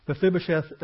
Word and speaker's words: Mephibosheth: But now that we have his name, and I've already mispronounced it several times, Mephibosheth, the sheth is shame.